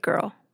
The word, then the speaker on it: Grrrl: The movement came to be called Riot Girl. That's girl spelled with no I and three R's. It's meant to be pronounced with a sort of growl.